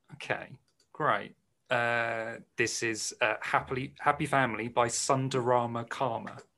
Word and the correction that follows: Sundara Karma: Sundara Karma. – I definitely said it wrong.